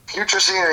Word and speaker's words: putrescine: Putrescine and Cadaverine, yes.